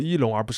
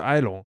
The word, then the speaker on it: Elon: 其次，他的名字应该读作伊隆而不是埃隆。